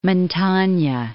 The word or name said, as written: Montagna